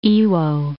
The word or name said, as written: Iwo